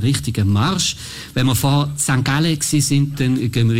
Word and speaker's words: St. Gallen: Ja, für alle in Bhutan oder irgendwo, wo es hört, so spricht man St. Gallen aus.